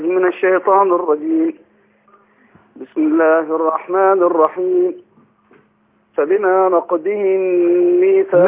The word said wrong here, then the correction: نقضهم: لا نقضهم كده انت نطقت الضاد دال. نقضهم لو نقضهم ضهم. – فبما نقضهم ميثاقهم وكفرهم بآيات الله وقتلهم الأنبياء بغير حق وقولهم قلوبنا غلف بل طبع الله عليها بكفرهم فلا يؤمنون إلا قليلا.